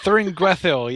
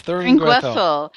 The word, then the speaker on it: Thuringwethil: I'd love if you could talk about the vampire whose name I can't really pronounce, Thuring Withil. – Thuringwethil, yes, Thuringwethil.